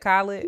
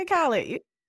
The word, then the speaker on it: Khaled: How you say it? I said Khaled. – You say Khaled. – I said it right, right? – You said it right.